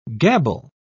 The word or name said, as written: gabble